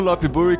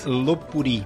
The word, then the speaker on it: Lopburi: Eu falo Lopburi com sotaque em inglês. Como é que fala Lopburi em tailandês? Lopburi. Lopburi. Lopburi.